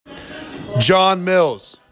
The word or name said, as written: JOHN MILLS